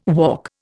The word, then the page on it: walk: Indian